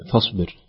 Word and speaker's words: فاصبر: طيب شيخ يوسف حفظك الله حين ذكرت في موضوع فاصبر الصاد هنا نطقها يكون في تفخيم يبين هذا التفخيم ولا